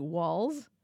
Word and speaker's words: Walz: who's Tim Walz? How do we even say his name? – Yeah, right. I think I've been saying it wrong too. Walz? Like W-A-L-Z, everyone.